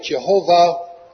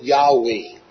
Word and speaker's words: Jehovah: Four letters in the Hebrew J-H-V-H, or Y-H-W-H. Some pronounce it Jehovah, others pronounce it Yahweh.